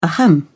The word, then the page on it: ahem: British English